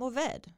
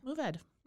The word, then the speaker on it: Mourvèdre: I pronounce this varietal as Mourvèdy. It is a French word. Mm-hmm. Mourvèdy. – Mourvèdy. – Mourvèdy. And it's spelled Mourvèdre. Mourvèdy.